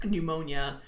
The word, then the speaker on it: pneumonia: So if you have something like pneumonia, you don't have umonia, pne, or something like that, because the P is silent.